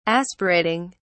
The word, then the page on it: aspirating: British English